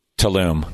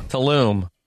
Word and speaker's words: Tulum: Am I pronouncing that right? – Tulum. – Tulum.